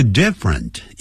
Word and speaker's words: different: 这个 different 也是两个音节不要念成 different different different